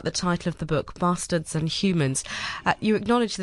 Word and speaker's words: Bastaards: It's Bastaards with two A's for those that are listening and trying to work out why we're swearing on exactly.